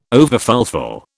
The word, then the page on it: overfulfil: British English